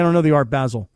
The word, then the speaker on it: Basel: And he's like, yeah, we're going down to Art Basel. Is that how it's pronounced? – Art George Basel, yep.